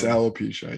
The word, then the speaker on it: alopecia: It's alopecia, yeah.